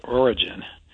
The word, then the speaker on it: Origen: I believe the famous church father, I almost hesitate to mention this, Origen, and that's how you pronounce it, Dave, I checked.